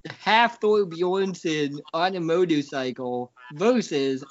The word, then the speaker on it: Hathor: Hathor. I think it's pronounced like half of Thor. Like Hathor.